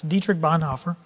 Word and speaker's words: Dietrich Bonhoeffer: It's Dietrich Bonhoeffer.